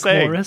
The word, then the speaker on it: McMorris: Also, wait, how are you pronouncing it? – McCorris? – McMorris. – Mark McMorris. – That's what I said. Oh, okay. – Yeah. Dropping an M there, Sean.